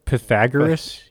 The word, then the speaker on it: Pythagoras: Pythagoras. You can't do Pythagoras. – Shut up. – Like the Pythagorean theorem that we all learned in middle school. – Pythagoras had a different idea, but still reincarnation.